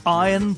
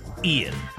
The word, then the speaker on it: Ian: As well as learning how to pronounce his first name as well, Ian.